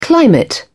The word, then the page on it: climate: British English